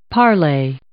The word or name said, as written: parlay